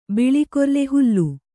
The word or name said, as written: ಬಿಳಿಕೊರ್ಲೆ ಹುಲ್ಲು